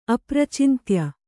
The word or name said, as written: ಅಪ್ರಚಿಂತ್ಯ